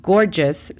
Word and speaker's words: gorgeous: La pronunciación fonética sería G-L-O-R-I-A-S, con la pronunciación correcta de la letra R, glorious. La segunda palabra es gorgeous. Gorgeous significa espléndido o espléndida. La pronunciación fonética sería G-O-R-G-I-A-S. Gorgeous.